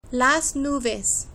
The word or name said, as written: las nubes